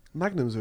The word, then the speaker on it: Magnum: Apparently pronounced Magnum in Italy. They were pronouncing Magnum. – Oh, because yeah, that's how they say it, the G, isn't it?